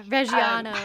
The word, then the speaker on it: Reggiano: I did the like Reggiano. I don't know. I'm not Italian. I don't know how to say it. – Oh yeah. Reggiano.